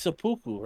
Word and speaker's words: seppuku: Is it seppuku or senbuku? There's no M in seppuku. – Senbuku. – It's seppuku, right? – I just want to kill myself, basically.